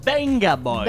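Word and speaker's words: Vengaboys: Ce qu'il faut savoir, c'est le V dans le nom du groupe de Benga Boys se prononce comme un B. – Benga Boys.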